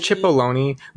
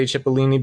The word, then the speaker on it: Cipollone: It's C-I-P-O-L-L-O-N-E. – I think it's Cipollone, but I like that we say Cipollini because I just picture him as a tiny onion.